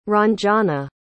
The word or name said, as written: ranjana